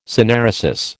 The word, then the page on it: synaeresis: American English